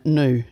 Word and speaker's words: gnu: I don't want to undermine your whole project here, but I understand that the G is silent in Gnu. Do you not pronounce it Gnu?